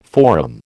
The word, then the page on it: forum: American English